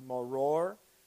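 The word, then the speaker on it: maror: There's the bitter herb, which is pronounced moror, and it's kind of like a horseradishy herb to represent the bitterness of slavery and how bitter life was in slavery.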